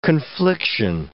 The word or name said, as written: confliction